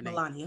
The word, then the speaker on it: Melania: like Melanie Trump. I don't even know how to say the girl Melania.